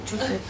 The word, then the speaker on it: Chusid: V roce 2000 přišel, jak to vyslovujeme, Irvin Chusid? – Chusid, ale Chusid. Jako nejsem si jistá. – Já myslím, že Chusid. – Tak Chusid.